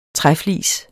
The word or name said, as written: træflis